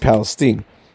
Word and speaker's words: Palestine: Now, don't try to correct me and tell me Palestine, because that's not the way Palestinians, that is, Palestinians from Palestine, Texas, pronounce that, the name of that town. They pronounce it Palestine.